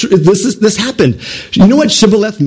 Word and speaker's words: Shibboleth: Then they would say to him, "Then say Shibboleth." And he would say, "Sibboleth." They couldn't say the SH, for he could not pronounce it right.